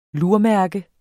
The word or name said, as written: lurmærke